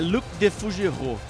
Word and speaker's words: Luc Defougeroux: Mas como é a pronúncia aí? – É Luc Defougeroux.